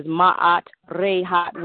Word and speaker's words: Ma'at Re Hat: It is Miriam Canty, and that's M-I-R-I-A-M. Canty, C-A-N-T-Y. My tribal name is Ma'at Rehat, which is Justice in Ray's House, and that's spelled M-A-A-T space R-E space H-A-T.